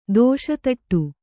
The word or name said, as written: ದೇಶೀತೋಡಿ